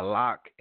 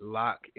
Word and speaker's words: Lak: The last track right there was by Lock, L-A-K. That's how you spell it, Lock, L-A-K.